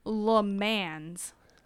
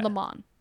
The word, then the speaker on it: Le Mans: It looks like Le Mans. Oh, you don't pronounce the S. – Yeah. – Le Mans.